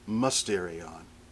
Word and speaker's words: mysterion: Curtis Vaughan writes that the Greek mysterion, it's spelled mysterion, as if it's mysterion, which makes it clear we get the word mystery from it, but it's pronounced mysterion.